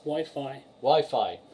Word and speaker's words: Wi-Fi: News on Wirefire. I said it right for once. On Nintendo. – Wi-Fi. – Said it wrong.